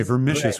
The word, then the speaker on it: vermicious: Let's see if I'm pronouncing this right. Vermicious. – Oh, excellent. Is that vermicious. That okay, vermicious. Correct.